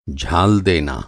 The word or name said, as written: झालना